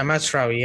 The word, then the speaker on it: Mazraoui: In Mazraoui, am I pronouncing it right? Yeah, Mazraoui.